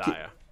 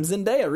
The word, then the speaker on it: Zendaya: The chat wants you to know that it's Zendaya. – Oh, thank Zendaya. – You. I've said it wrong for a long time. Zendaya, really.